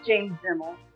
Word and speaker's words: Demmel: It's James Demmel. Um, how are you doing today, James? – I'm doing great, especially because you pronounced my last name perfectly.